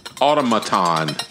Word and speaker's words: automaton: Automaton, that's actually, I said automation because I'm an idiot and I've been drinking, but it's automaton.